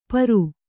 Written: ಪರು

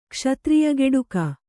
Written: ಕ್ಷತ್ರಿಯಗೆಡುಕ

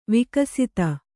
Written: ವಿಕಸಿತ

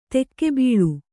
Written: ಧಕ್ಕೆಬಲಿ

